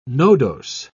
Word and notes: nodose: British English

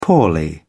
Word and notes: poorly: British English

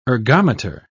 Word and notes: ergometer: British English

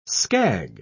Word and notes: scag: British English

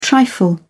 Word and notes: trifle: British English

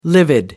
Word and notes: livid: American English

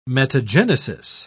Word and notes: metagenesis: British English

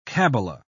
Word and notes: kabbala: British English

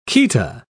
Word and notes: chaeta: British English

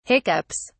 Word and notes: hiccups: British English

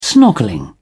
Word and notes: snorkelling: British English

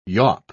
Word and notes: yaup: English; British English

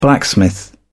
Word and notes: blacksmith: British English

